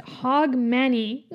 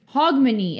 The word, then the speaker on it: Hogmanay: In which country are the New Year's Eve celebrations known as Hog Manny? – Manny is hands. Italy. – Scotland. – Oof. – Hogmanay.